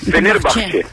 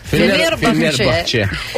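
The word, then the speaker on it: Fenerbahçe: Φενέρμπαχτσε. – Φενέρμπαχτσε.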